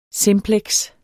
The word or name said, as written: simpleks